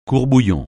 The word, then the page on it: court-bouillon: French